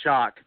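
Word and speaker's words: Shough: It's S-H-O-U-G-H, but I think it's pronounced Shock.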